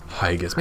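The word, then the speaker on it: Heigus: Hegis.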